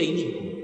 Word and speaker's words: inicuo: En Proverbios 12:2 leemos, el que es bueno consigue aprobación de parte de Jehová, pero el hombre de ideas inicuas, él lo pronuncia inicuo.